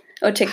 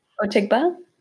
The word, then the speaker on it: Otigbah: Otigba. – How do you say her last name? – Otigba.